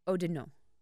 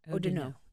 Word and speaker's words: Oudinot: Champagne, O-U-D-I-N-O-T. Odinot. – Odinot. – Okay. And I'm probably butchering that. – And all I know is that the last consonant's not pronounced unless there's any after it.